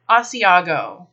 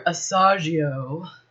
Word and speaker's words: Asiago: Let's look at how this word is spelled. The next cheese is Asiago. A-S-I-A-G-O. There is an I before the second A in Asiago. Ignoring that fact, a lot of people come in looking for Asagio.